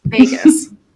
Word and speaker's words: Vegas: You're pronouncing it like it has egg in it, like Vegas. It's adorable. Oh, well, you're so Canadian. I love it. It's Vegas. – Vegas.